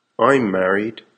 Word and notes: I'm married: American English

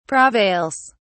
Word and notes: prevails: British English

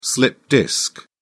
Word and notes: slipped disc: British English

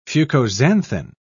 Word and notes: fucoxanthin: British English

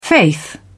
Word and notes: faith: British English

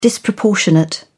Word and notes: disproportionate: British English

